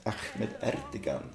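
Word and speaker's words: Ahmet Ertegun: Och ägaren där, Ahmed Ertegan, som du kanske känner till det namnet, han peppade Ray Charles. – Det är fint uttal där, tycker jag, fanns namn.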